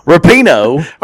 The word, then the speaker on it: Rapinoe: Megan Rapamo. Rapaho. Rapinoe. Oh, Rapinoe. Yes.